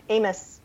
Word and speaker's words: Amos: Hey, Jamie, do you say your last name Amos or Amos? – Amos.